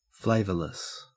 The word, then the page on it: flavorless: Australian English